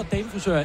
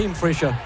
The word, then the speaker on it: Damefrisør: hedder damefrisør efter det danske navn damefrisør, men som har misforstået, hvad man udtaler, og har givet utrolig mange interviews, hvor de siger som en guide, hvordan man udtaler det rigtigt på dansk, og siger, at det hedder Dame Frisher. – Det er simpelthen ikke rigtigt, venner.